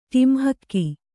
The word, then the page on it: ಟಿಂಹಕ್ಕಿ: Kannada